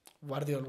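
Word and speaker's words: Gvardiol: ¿Se dice Guardiol? Guardiol, Guardiol. Guardiol, Guardiol. Eh, es una pronunciación muy rara, pero sí, Guardiol.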